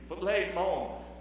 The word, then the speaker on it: Philemon: In the title of this story, this whole letter, this postcard, it's Philemon is the way you pronounce it in the Greek. Philemon.